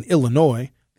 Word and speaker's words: Illinois: In fact, our very, very good friends to the south of us in Illinois, the S is silent in Illinois, by the way,